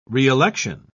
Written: reelection

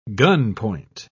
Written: gunpoint

